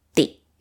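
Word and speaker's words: Di (ติ): The word for to criticize is Di. Short one. Di.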